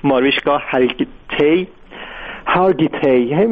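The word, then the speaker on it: Mariska Hargitay: ماریشکا هارگیتی اسمش اینجوری تلفظ میشه، این برای تیلر سویفت توی مجله تایم نوشته که گفته که خیلی مستعد پدیده موسیقی پاپ.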